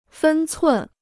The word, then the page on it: 分寸: fēn cun